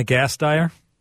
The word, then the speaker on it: Gasteyer: Anna Gasteyer.